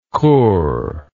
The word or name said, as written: kur